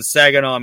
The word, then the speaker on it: Saginaw: All right, so um, WCW Nitro, April twenty first, nineteen ninety seven, is in Saginaw, Michigan.